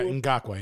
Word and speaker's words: Ngakoue: Yeah, Ngakoue, yeah.